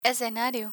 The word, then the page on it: escenario: Spanish